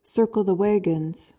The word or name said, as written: circle the wagons